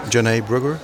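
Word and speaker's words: Janai Brugger: Nederlands Philharmonisch Orkest onder leiding van James Gaffigan met, am I saying this right, Janae Brugger? – Ja.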